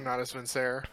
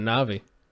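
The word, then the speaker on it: Natus Vincere: I'm going with Blade from Not As Vincere. – That's how you... Oh my god, that's how you pronounce it. I've always called it Navi.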